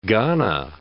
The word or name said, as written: Ghana